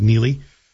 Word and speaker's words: Neagley: Francis Neely, and by the way, if you're looking for it on Amazon, it's spelled N-E-A-G-L-E-Y. Sort of looks like Neely, but it's pronounced Neely.